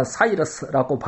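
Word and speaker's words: Cyrus: 이 고레스는 이 영어로는 사이러스라고 발음을 하지요.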